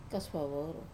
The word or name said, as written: kasuvavoru